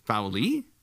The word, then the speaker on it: Fowley: She was recruited by Kim Fowler. Fowley? – Mm-hmm. – Oh, okay. I've got the name wrong. Sorry.